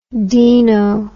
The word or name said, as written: deano